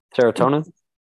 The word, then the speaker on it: serotonin: this amount of serotins, uh, eh, serotins is how you say uh, serotonin.